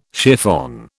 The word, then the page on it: chiffon: British English